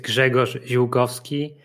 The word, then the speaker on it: Grzegorz Ziolkowski: So my name is Grzegorz Ziolkowski.